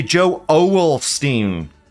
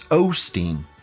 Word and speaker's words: Osteen: I want to say Joe Owlstein so badly. It's just, it just wants to come out so badly, but I know it's not how you say it. It's Osteen. It's Osteen. No, there's no L in there.